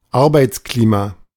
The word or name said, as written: Arbeitsklima